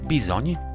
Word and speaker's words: bizony: Uczący się węgierskiego dowiedzą się, że tutaj to tratwa, Swojskie w pisowni słowo bizony wymawia się nieco inaczej bizoń i oznacza ono z pewnością.